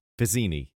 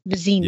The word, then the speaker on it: Vizzini: Yes, Vizzini. – V-I-Z-Z-I-N-I, Vizzini. – Yeah, but when Mandy Patinkin pronounced it, he goes, Vizzini.